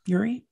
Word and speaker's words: Urie: Is that how you say his name? Urie? – Yeah.